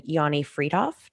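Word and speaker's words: Yoni Freedhoff: and then Canadian physician Yanni Friedhoff, did I say that correctly? – Yeah.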